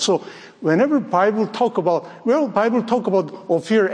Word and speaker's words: Ophir: Christ guarantees that he will make a man more precious than fine gold, even a man than the golden wedge of Ophir. Some people use the word Ophir, but I checked it out. The real correct pronunciation is, which I learned this morning, Ophir.